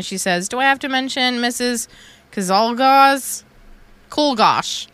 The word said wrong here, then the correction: Czolgosz: Cholgosh. But it's spelled C-Z-O-L-G-O-S-Z. Cholgosh. – Cholgosh. She even wrote the pronunciation. I still got it wrong.